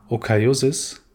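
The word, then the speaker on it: Oikeiosis: Und heute wollen wir anfangen mit einem ganz klar besonderen Konzept der Stoiker und zwar der Okaiosis. Habe ich es richtig ausgesprochen, Markus? – Ja, das passt schon, äh, okay.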